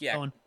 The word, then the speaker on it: Ghosn: It's pronounced Ghosn.